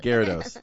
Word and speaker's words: Garridos: Oh, I'm going to say the name wrong. I'm going to say Garpadook. – My name is Garridos. – Garridos. Oh, I guess I got the G part.